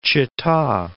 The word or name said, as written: Chita